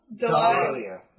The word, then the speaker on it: Dahlia: Dahlia.